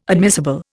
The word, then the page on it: admissible: Indian